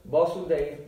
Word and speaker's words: Vasudev: That is Vishuddha Sattva, is known as Vasudev, that's V followed by a short A. And then the Supreme Lord is known as Vasudev, V followed by a long A.